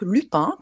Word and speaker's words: Lupin: Est-ce que je prononce bien Lupin ? – Exactement, voyez, nouvelle série, peut-être ça vous a échappé à la maison si vous êtes moins sur Netflix que d'habitude.